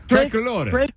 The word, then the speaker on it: Tricolore: Wait, how do you say it? – Tricolore. – Oh, yeah, three colors. – Tricolore. Oh, that just sounds fun to say.